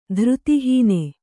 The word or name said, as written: ಧೃತಿಹೀನೆ